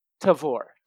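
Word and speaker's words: Tavour: It is Tavor. Tavor. – Okay, like Tavor. – Flavor and or taste and flavor. Tavor. – Oh. – Yeah, Tavor. Tavor. I don't know. – Tavor.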